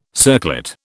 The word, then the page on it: circlet: British English